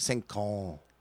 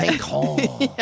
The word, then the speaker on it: cinq ans: And I'll correct, you know, I don't try to like be an asshole about correcting them, but it's like cinq ans. And they're like, oh, is that how you say it? And I'm like, yeah, you just gotta be really French. Like, yeah. Cinq ans.